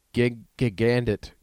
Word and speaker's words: Gigandet: Cam Gigandet. How do you say it? How do you say it, though? It's not that. It's like Smith or something, but it's Cam Gigandet.